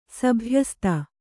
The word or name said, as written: ಸಭ್ಯಸ್ತೆ